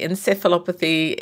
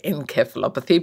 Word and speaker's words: encephalopathy: In the US, you say encephalopathy. In Australia and England, you say encephalopathy, but thankfully we all understand each other.